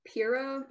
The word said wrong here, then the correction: Fera: Muntar? – Fira. – Fira. – F-E-R-A. Okay.